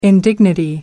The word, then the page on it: indignity: American English